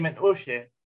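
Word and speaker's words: man'oushe: Are you trying to say menoshe? – It's probably that. I'm sorry if we do have any Lebanese people listening. My pronunciation. – Because you said meshi, as in I don't want anything.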